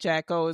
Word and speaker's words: Jaco: I looked up the pronunciation because I wasn't trying to be caught out there this time. And I think I think Jaco is right.